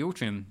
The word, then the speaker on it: Altrincham: I'm going to start throwing Altrincham. did I say that right? – Altrincham.